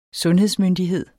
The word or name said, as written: sundhedsmyndighed